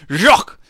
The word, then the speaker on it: Jacques: Or Jacques, I pronounced the S. And I even took three years of French. Air George, I apologize. Jacques.